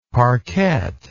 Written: parkette

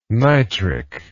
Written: nitric

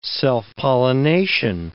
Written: self-pollination

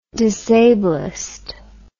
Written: disablism

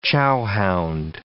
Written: chowhound

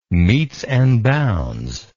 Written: metes and bounds

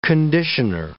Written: conditioner

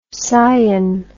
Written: cyan

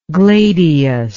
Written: gladius

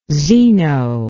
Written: zeno

